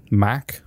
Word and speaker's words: Mach: Mach? – Mach. – Mach. – Pronounce it or spell it? – M-A-C-H. – Yeah, Mach.